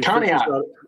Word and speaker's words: Conneaut: Says he's Conneaut.